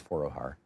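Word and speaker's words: Foroohar: Rana Forohar, I'm not certain how to pronounce her name, but F-O-R-O-O-H-A-R. I think it's Forohar.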